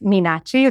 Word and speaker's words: Meenakshi: Did I say that correctly? – You didn't, but You just said it to me. – I'll say two things. One, my name is Meenakshi.